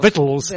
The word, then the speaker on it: victuals: Except I knew right away it's spelled victuals, but it's actually called vittles.